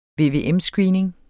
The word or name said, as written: VVM-screening